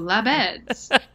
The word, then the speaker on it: Labedz: Whenever I do media or podcasts, they ask how to pronounce my name, and I say it's like you were making up where French people sleep. You'd say, oh, Labedz.